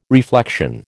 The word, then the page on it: reflection: American English